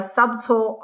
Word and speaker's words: subtle: And then I'm reading The Subtle Art. I can't believe I said subtle because my boyfriend teases me about that all the time. I pronounce the B and you can't pronounce the B. The Subtle Art of Not Giving a F-U-C-K.